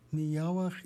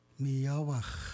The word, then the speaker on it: Miyawak: Miyawak is how you say black bear in Ichishkin. Miyawak.